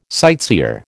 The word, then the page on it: sightseer: American English